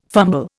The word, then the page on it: fumble: Indian